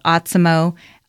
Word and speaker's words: Otsimo: So definitely check out Autismo. O-T-S-I-M-O is how it's spelled.